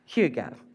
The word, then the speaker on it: hygge: After much research this week, I have discovered it is indeed pronounced hygge.